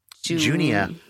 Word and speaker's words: Junia: It's very short, but it's her name's like, it's spelled J-U-N-I-A. – Junia. – Junia, maybe.